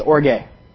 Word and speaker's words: orge: Um, the word for wrath in Greek is orge. O R G E. And yes, it is pronounced like I said it. Orge.